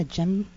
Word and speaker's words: djembe: And can you pronounce it again? A djembe? – Djembe. – A djembe? – Yes.